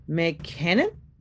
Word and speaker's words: McKinnon: I'm going to make sure I said his name right. McKinnon? Yes. Jerick McKinnon, he had 12 carries for 61 yards, while Travis Kelce had five receptions for 108 yards and one touchdown.